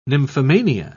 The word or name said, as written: nymphomania